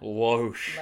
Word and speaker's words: Loesch: It's L-O-E-S-C-H. How would you pronounce that? – Loesch. – Loesch. Margaret.